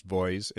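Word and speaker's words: VOEZ: Uh, Voice, I think that's supposed to be pronounced Voice. It's V O E Z.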